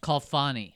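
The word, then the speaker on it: Khalfani: Kalfani.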